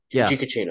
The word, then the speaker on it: Giacchino: On that tangent for just a moment, I will say that Michael Giacchino, if I'm saying his name right, who did the Batman? – Ficuccino. – Giacchino. Okay, yeah.